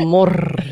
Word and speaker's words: Rigmor: Ja, och sen så på slutet så får man liksom bara veta att, att spöket heter Rigmor. – Mm. – Som är betoningen på morr liksom.